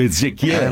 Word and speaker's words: Ezechiele: Ezechiale 25-17. – Recita? – Luca Ward, Jules Winfield di Pulp Fiction. – Ah, l'inglese hai sentito la pronuncia? – Vedi, ma l'italiano è Ezechiele.